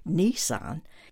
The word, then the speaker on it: Nissan: It's spelled N-I-S-S-A-N. It's not pronounced like the car, Nissan. It's Nissan.